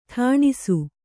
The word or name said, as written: ಠಾಣಿಸು